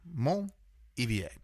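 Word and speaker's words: Montolivet: This is Clos de Monivet, or Clos de Monivet. – It's spelled M-O-N-T dash O-L-I-V-E-T. – Yeah, Ivier, basically. Mon-Ivier.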